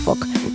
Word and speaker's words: folk: Ellos, el folk, creo que lo dicen sin L, que dicen folk, ¿eh? La música folk. Creo, ¿eh? Creo. Sí, sí, sí. Os diría que no pronuncian la L, al igual que ocurre en Walking. – Walking Dead. – En Walk. En Island no pronuncian la S. Y yo diría que en la música folk no dicen folk.